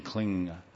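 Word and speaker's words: klīṁ: It's a nasal m, m, like ang, like m, like this, through the nasal, in pronunciation, kling.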